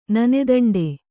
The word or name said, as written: ನಾಣ್ದಂಡ